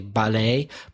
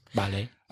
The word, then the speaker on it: ballet: The French, there is no real stress, except the last syllable is always stronger in any French word. So ballet. – Another word which changes its stress is A-D-U-L-T.